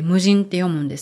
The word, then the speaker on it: 無人: ない人って書いて無人って読むんですけど、無人販売所で野菜が売られていたりします。